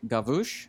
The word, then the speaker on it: Gavouche: Nick Gavouche, I think is how you say it. – Yes, he said it rhymes like the bush.